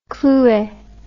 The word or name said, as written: clouet